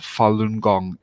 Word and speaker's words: Falun Gong: It's pr pronounced Falun Gong.